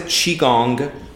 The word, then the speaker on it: Qigong: You pronounce it Qigong, but you can spell it with Q-I-G-O-N-G or C-H-I-G-O-N-G.